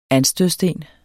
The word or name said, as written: anstødssten